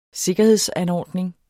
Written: sikkerhedsanordning

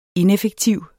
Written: ineffektiv

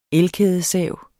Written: elkædesav

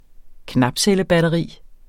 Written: knapcellebatteri

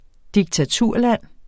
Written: diktaturland

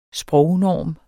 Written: sprognorm

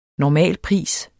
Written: normalpris

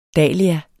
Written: dahlia

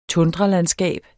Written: tundralandskab

